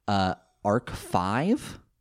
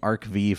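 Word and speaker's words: Arc-V: And then both of them were a few characters in, I guess I've been pronouncing the name of this series incorrectly, Arc 5. – Not Arc V? – I've been saying Arc V for a long time because there is no indication that this is a Roman numeral.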